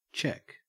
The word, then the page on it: cheque: Australian English